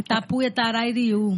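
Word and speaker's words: Tapuia Tarairiú: Isso me lembrou do seu povo, que é Tapuia, Tarai. Me corrija, que eu não sei pronunciar. Você pode dizer? – É Tapuia, Tarairiu.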